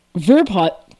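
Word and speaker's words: Verpaelst: My name is pronounced Verpaws. – Verpaws. Amazing. – The L and the T are silent.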